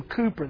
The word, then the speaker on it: Cowper: William, we pronounce it Cowper, C-O-W-P-E-R, but it's William Cooper, the famous hymn writer.